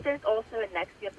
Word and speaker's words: NXIVM: How do you say that? Nexium. – Oh, it's Nexium. Ne- oh it is Nexium.